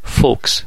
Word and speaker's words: folks: لغت folk ال تلفظ نمیشه folks